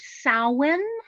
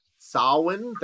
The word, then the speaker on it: Samhain: I want to tell you first, it's pronounced Samhain. – Samhain, thank you. – Samhain, yeah. – There we go, appreciate it. – It's Irish Gaelic, which is like, it's like French. They have a whole bunch of different letters and words that they don't say. – There you go. – So, and then they have some that are pronounced differently.